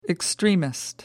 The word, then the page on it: extremist: American English